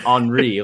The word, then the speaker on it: Henri: Onyekuru pronounces his name Henri, like Thierry Henry.